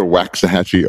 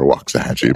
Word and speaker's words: Waxahachie: So it's either Waxahachie or Waxahachie, yeah. but either way. Either one.